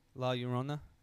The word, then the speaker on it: La Llorona: And the first time I ever heard of La Llorona, is that how you say it? – La Llorona. Mm-hmm.